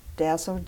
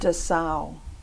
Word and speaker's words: Dassow: Dassow. So it's Dassow, like Darrow, like Arrow.